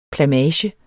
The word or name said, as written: plamage